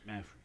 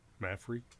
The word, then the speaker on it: Mapfre: How do you say that? Mafra Stadium? – Yes. – M-A-P-F-R-E? – Right. – I think. – Mafra. – Yeah, Mafra.